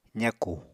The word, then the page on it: zakù: apatani